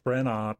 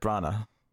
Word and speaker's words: Branagh: That was an interesting pronunciation of Branagh. – Oh, Branagh. The wrong emphasis. – I just say Kenneth Branagh, but I thought that's what I said.